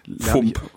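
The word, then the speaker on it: Phở: diese Pho, die, mhm. die ja komplett anders ausgesprochen wird, ungefähr oder so. – Fump. – Fump, genau, Fump. Bitte eine Fump und noch so, ein, so ein bisschen Zeugs.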